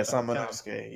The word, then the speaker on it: Samanosuke: Samanosuke. – Say it again. – Samanosuke or Samanosuke? – Yeah, Samanosuke. Yeah, that's how you say that shit.